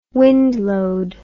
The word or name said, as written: wind load